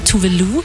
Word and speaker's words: Tuvalu: Aber sie hat uns eine kleine Eselsbrücke gegeben, nämlich Voulez-vous, also Französisch, Voulez-vous, wollen Sie? Voulez-vous, Tuvalu?